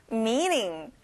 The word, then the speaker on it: meeting: 회의, 만남, meeting. Me를 길게 발음해 주세요. 그리고 T 발음은 안 나요.